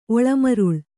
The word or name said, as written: ಒಳಮರುಳ್